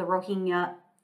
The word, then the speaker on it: Rohingya: He's an advocate and journalist for Myanmar's persecution of certain groups, for example, the Rohingya, and I apologize if I mispronounce that, Muslims.